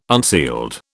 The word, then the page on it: unsealed: British English